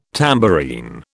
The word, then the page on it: tambourine: British English